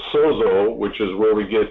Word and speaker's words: sozo: and it's pronounced sozo for the root here, but it says here, this is what the word means.